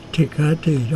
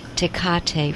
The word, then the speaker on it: Tecate: Tecate, almost like the beer. – The Spanish beer. Tecate, first earth, got to be a dead planet.